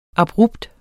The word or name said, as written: abrupt